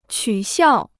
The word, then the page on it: 取笑: qǔ xiào